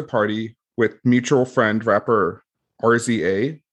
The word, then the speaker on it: RZA: It's RZA.